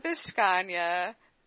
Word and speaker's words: Vishkanya: Anyway, my name is Lana Vishkanya. The last name is spelled V-I-S-H. K-A-N-Y-A.